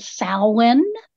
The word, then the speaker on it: Samhain: Right, and we actually pronounce that Samhain.